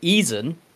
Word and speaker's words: Eisen: Frieren, and I believe the dwarf would be called Eisen, because that's how Germans pronounce E followed by I. Well, sorry, I followed by E.